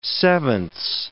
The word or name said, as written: sevenths